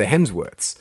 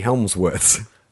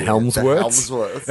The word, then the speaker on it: Hemsworths: He referred to the Hemsworth twice as the Helmsworths.